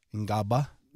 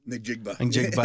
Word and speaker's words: Njigba: 21 is Jackson Smith Ngaba. – Njigba.